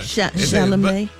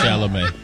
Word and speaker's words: Chalamet: Chalamet. – You was close, though, you say. – You ever thought about going back? You ever thought about going back to kindergarten? – It's like Chevrolet. Oh, not Chevrolet.